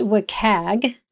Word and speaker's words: WCAG: Finally, before we jump in, one of the accessibility guidelines that we talk about in our conversation is pronounced WCAG, which is an acronym.